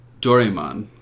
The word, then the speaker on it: Doraemon: Doraemon? – Doraemon.